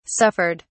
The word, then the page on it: suffered: British English